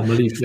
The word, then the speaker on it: Maleev: Nou, er komen volgens mij, was laatst iets met Kickstarter, waren een paar artbooks die uit gaan komen van Alex Malief, of Maleev, ik weet niet hoe die precies uitgesproken wordt. – Ja, Malief, ja, Malief.